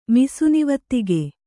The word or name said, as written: ಮಿಸುನಿವತ್ತಿಗೆ